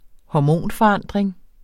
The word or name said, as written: hormonforandring